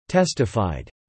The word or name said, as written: testified